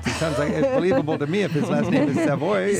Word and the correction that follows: Savoie: Savoie. It's pronounced Savoie, like the region.